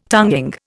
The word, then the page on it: tonguing: Indian